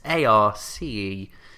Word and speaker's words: Arceus: And I think it's pronounced Arceus. Arceus. It's Arceus. It's a bit different.